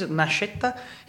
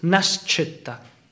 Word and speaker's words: Nascetta: Nascetta. – Nascetta. – Nascetta. – Or there's two ways. If you say in Italian ways, Nascetta. If you say in our dialect, in Piemontese dialect, is Nascetta.